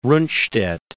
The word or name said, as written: Rundstedt